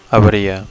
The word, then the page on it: avaria: Catalan